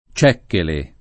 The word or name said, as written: Cecchele